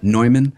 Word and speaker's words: Neumann: I cannot, sadly, afford their, what is it, their Neumann U37? – Neumann, yeah. – Neumann, is that how you pronounce that? Neumann? Mm-hmm.